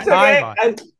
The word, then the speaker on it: Kayvan: I said Kayvan. I tell people it's like Avon.